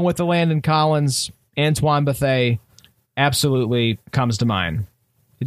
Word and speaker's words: Bethea: He was like, yeah, man, it's Bethea.